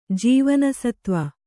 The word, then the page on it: ಜೀವನಸತ್ವ: jīvana satva